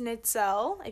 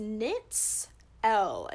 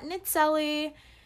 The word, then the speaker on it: Nitzelle: Okay, so my name is Nitzelle, if you don't already know. It is pronounced Nitz L. It's not Nitzelli. It's not Natel, it's not pretzel, it's not any of those things. It is Nitz L.